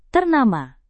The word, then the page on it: ternama: indonesian